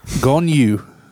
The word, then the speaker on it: Gong Yoo: Gong Yoo.